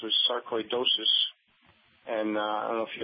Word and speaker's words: sarcoidosis: It's pronounced sarcoidosis.